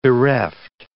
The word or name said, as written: bereft